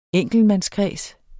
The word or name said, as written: enkeltmandskreds